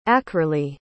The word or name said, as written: ackerley